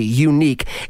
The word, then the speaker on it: Yuneec: The company is called Unique. I love the way they spell it. Y-U-N-E-E-C. Unique.